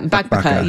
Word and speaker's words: bakbaka: There's another recipe, and boy, I'm not confident that I will pronounce this correctly, but it's the one pan Bakbaka. Yeah, bakbaka. Yeah, yeah, that's it. You did well.